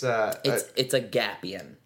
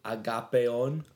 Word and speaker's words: Agapion: Because, like, if I pronounced it, like, the right way, it would be Agapion.